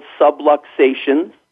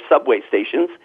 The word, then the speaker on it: subluxations: That is that there are these things called subluxations, or some people mispronounce it and say they're subway stations,